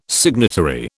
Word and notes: signatory: British English

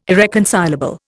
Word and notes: irreconcilable: Indian

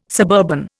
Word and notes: suburban: Indian

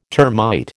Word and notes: termite: American English

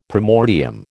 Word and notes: primordium: American English

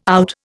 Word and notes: out: Indian

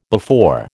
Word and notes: before: American English

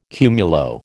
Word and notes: cumulo: American English

